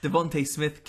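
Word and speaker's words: Devonte: Devontae Smith, Kennedy Neshkukwu. – That guy. See, I said Devontae. So Devontae Smith. – It's definitely Devontae.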